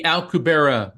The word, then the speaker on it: Alcubierre: The Alcubierre metric.